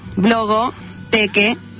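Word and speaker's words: Blogothèque: Es blog, blogoteque, o sea, T-H-E-Q-U-E. ¿Se entendió? – Sí, se entendió.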